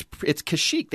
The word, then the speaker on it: Kashyyyk: And it's Kashyyyk. They actually had it written out, the K-A-S-H-Y-Y-Y-K. Pronounced Kashyyyk and everything you've ever heard, including the original, including the prequel trilogy, they actually said the name.